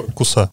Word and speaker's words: Куса: Правильно ударение? Куса. Ближайшее расположение — это город Куса.